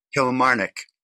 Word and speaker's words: Kilmarnock: How do you pronounce it? – Kilmarnock.